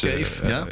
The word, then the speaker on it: Kaeve: Hè, dus het tweede pand in Cave. Dus Cave, in Enter. – K-A-E-V-E. – Precies, dat is een man cave, maar dan op zijn Nederlands uitgesproken.